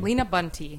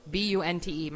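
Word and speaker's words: Lina Bunte: But the way it's spelled, LinaBunte. My whole life I've got Lina Bunt, Lina Bunta. I was in the newspaper once as Line Bunt.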